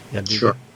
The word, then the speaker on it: Jadwiga: An underground passage, thought Queen Jadwiga. I guess is that how you pronounce it? – Jadwiga. Sure.